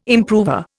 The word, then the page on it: improver: Indian